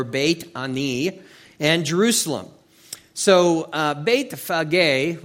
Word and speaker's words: Bethphage: Verse 1 says, "Now when they drew near to Jerusalem and came to Bethphage," is how that's pronounced, but you can say Bethphage, you know, "at the Mount of Olives, then Jesus sent two disciples."